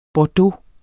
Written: bordeaux

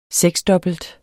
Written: seksdobbelt

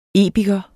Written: epiker

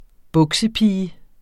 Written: buksepige

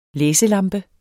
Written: læselampe

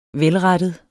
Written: velrettet

